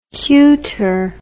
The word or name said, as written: kyuter